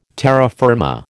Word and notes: terra firma: American English

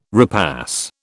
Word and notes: repass: British English

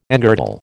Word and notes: engirdle: American English

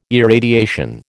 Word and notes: irradiation: American English

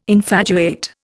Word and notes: infatuate: Indian

